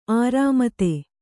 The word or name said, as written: ಅರಿಮೇದ